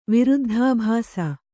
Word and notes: ವಿರುದ್ಧಾಭಾಸ: viruddhābhāsa

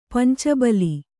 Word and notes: ಪಂಚಬಲಿ: kannada

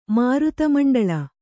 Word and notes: ಮಾರುತಮಂಡಳ: kannada

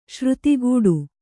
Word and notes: ಶ್ರುತಿಕಟು: śruti kaṭu

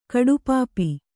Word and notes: ಕಡುಪಾಪಿ: kaḍupāpi

